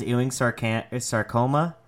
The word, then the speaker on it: Ewing sarcoma: And Lindblom, if you guys don't know, has Ewing sarcoma. I don't even know how to fucking say that. It's you said it right.